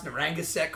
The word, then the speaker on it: Narragansett: I love that you even got, you pronounced Narragansett correctly. That was beautiful.